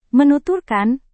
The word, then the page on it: menuturkan: Indonesian